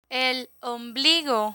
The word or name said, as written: el ombligo